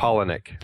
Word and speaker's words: Palahniuk: Polinick. Polinick. Polinick. Polinick. Yeah. – Polinick yeah, Chuck Palahniuk.